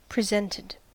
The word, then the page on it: presented: American English